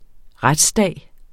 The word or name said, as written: retsdag